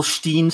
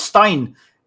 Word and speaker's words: Stein: I hate that whole Steen stuff, and people even like spell it as Steen. It's like, no, it's Stein. It's German for stone, and it's Stein.